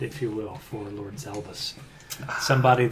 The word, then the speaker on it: Zalbus: Next we have our big bad, which we already know is Lord Zalgus. – Lord Zalgus. – The Space Lord... Oh, I'm sorry, Zalbus. Z-A-L-B-U-S.